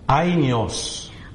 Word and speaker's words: Aionios: That is Aionios. – アニオスという発音で。